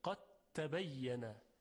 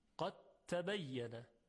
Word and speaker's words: قد تبین: خب تبین در اینجا ادغام انجام میشه، یعنی دال در حرف تا ادغام میشه، قد تبین، قد تبین. ببینید دیگه هیچ اثری از دال وجود نداره، قد تبین حرف تی مشدد هست یا تا مشدد. پس تلفظ دو حرف که دال و تا باشه با صدای حرف دوم که تا باشه با تشدید، قد تبین الرشد من الغی.